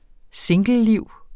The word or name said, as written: singleliv